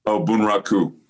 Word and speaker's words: Bunraku: Bunruku. I'm not sure if I'm saying that right. – Bunraku. – Bunraku.